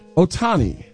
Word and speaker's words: Ohtani: Ohtani. – Ohtani.